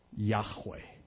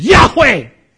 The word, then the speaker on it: Yahweh: That's not the way it's pronounced. Best guess is it was something like Yahweh. And Jesus simply stands. And he says, "Yahweh, And all 600 people fall on their face.